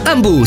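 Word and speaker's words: Hambu: Ambu si scrive con l'H.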